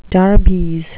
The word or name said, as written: darbies